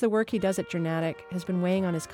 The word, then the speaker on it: Journatic: And it is pronounced Journatic, by the way. Timponi says it's a mix of journalism and automatic.